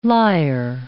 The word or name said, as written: lyre